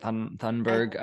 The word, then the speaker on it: Thunberg: That's how you say it, Thunberg.